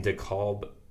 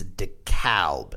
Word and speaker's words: DeKalb: Illinois, excuse me, in DeKalb, if I'm saying that right. – You are. – Illinois. – It's actually, so I recorded our interview last night with our guest, and it's DeKalb, and he was quite clear about that and insists on he called it the Chicago A.